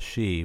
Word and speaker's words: Sidhe: You know that there is there's a brewery, I believe in Minnesota, called She, but it's spelled like SIDHE, and it's the only all women Wiccan brewery.